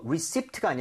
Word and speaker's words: receipt: 이거 그나저나 영수증 할 때 이거 receipt가 아니라 receipt입니다. P는 묵음입니다.